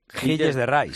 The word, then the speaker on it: Gilles de Rais: Es que claro, los franceses ya sabes que escriben las palabras y luego las pronuncian. – Sí, porque en verdad es Gilles de Rais. – Gilles de Rais, claro.